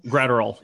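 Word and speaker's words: Graterol: Bruce Stark Gratterall? – Gratterall, yeah.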